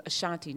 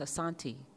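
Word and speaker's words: Asante: I always thought it was Ashanti. No, it's Ashanti. Ashanti, A-S-A, not S-H. Ashanti Kingdom.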